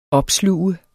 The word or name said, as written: OPSLUGTES